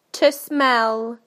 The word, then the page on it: to smell: British English